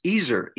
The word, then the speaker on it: Ezer: And you have to go into the Hebrew word helper, and it's pronounced Ezer, E-Z-E-R, which appears 21 times in the Old Testament.